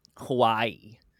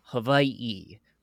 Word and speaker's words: Hawaii: And Butters' father says something to the effect of, you know, only Haoles pronounce it Hawaii. And he pronounces it Hawaii, right? So he really enunciates the W in the V sound,